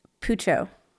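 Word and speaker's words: Puchao: It's similar to a haichu, but it's not as easy to pronounce. It's pucho. – Pucho. – Spelled P-U-C-H-A-O, I think.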